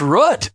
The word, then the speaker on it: Rutt: It's Rutt, rhymes with foot. – Oh, it's Rutt.